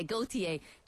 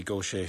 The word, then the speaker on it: Gauthier: And it's spelled T, because I keep on being tempted to say Gauthier, G-A-U-T-H-I-E-R, but it's the Cajun pronunciation is Gauthier. That's right. – How it's pronounced, yeah.